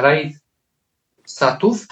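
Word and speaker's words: Riad Sattouf: E, Raid Satów, tak to się wymawia? tak.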